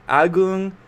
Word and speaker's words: Agung: It's Agung Anaka. How do you pronounce your name? – It's Agung Anak Agung Gede, but you got the Agung perfectly right.